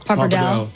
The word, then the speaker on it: Pappardelle: Pappardelle.